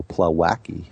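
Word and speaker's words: Plawecki: Plowacki or whatever. – I'll go with that guy on the Mets. Plowacki, you said it right the first time.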